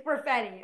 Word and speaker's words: Perfetti: It's Perfetti.